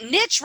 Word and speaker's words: niche: Oh, by the way, I love the fact that you say niche instead of niche. – Thank you. – Because my French mother, though, would probably not like it. – Not have liked it, but niche rhymes with rich.